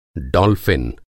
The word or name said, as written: डॉल्फिन